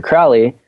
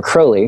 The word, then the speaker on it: Crowley: And the last one I listened to was mostly about Aleister Crowley, or Crowley, excuse me. Apparently that's the way you pronounce it.